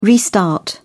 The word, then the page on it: restart: British English